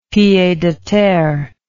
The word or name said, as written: pied a terre